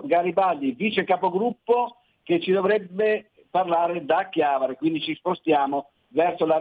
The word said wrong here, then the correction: Chiavari: Sei a Chiavari, ho detto bene oggi? Sì, sono a Chiavari,